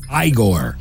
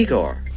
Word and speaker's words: Igor: This classic flick featured a character whose boss mispronounced his name as Igor. – You must be Igor. – No, it's pronounced Igor.